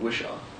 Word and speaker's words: Whishaw: You had a lot of good scenes with Ben Whishaw. I don't know if I pronounced his last name. – No, you're bang on. You're bang on. – Okay. Ben Whishaw.